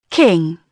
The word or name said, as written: king